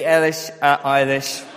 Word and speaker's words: Eilish: Have I got it right? No, Billie Eilish. Eilish, there you go. But, thank you. Yes. Billie Eilish, 17-year-old singer-songwriter.